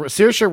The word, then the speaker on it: Saoirse: It's Saoirse. – Saoirse Ronan.